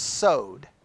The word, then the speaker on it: Sod: All right, the next one, S O D. S O D. It's not pronounced sod, it's pronounced sowed.